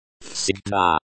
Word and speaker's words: Всегда: Всегда. Всегда. Colon, the stress is on the second syllable.